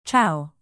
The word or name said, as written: ciao